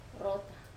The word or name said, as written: rota